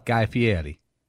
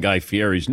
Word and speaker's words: Guy Fieri: So the R in Fieri is like more like an L, like Guy Fieri.